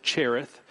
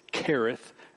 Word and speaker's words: Cherith: Now, Kereth, some would pronounce it Cherith. It is spelled with a C-H as you see it there. But I think the correct Hebrew pronunciation is Kereth, as if it were to say K-E-R.